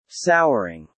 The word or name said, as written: souring